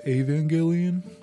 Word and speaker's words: Evangelion: He watched Evangelion. – It's pronounced Evangelion. – It's spelt Evangelion. Yeah, so. I mean, there's an I in there.